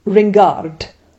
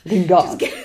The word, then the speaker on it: Ringard: You made it a bit Spanish, I thought. – I did. I'm so sorry. How would you do it in French? Hang on. Hello, hello. Ringard.